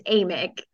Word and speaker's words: Amick: It's a long A. It's Amick. – Amick, Stephanie Amick.